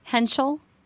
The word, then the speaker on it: Henschel: It's Henschel, just like a sneeze.